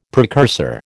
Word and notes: precursor: American English

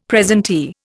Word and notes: presentee: Indian

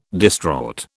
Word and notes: distraught: British English

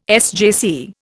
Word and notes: SJC: Indian

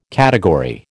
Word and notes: category: American English

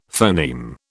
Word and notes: phoneme: British English

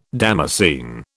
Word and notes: damascene: British English

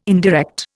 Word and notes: indirect: Indian

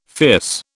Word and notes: fierce: British English

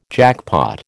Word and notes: jackpot: American English

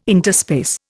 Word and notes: interspace: Indian

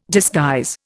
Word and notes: disguise: Indian

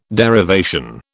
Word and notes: derivation: British English

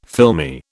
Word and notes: filmy: British English

